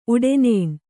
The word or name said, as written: ಉಡೆನೇಣ್